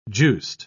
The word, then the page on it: juiced: British English